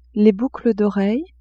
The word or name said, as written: boucles d'oreille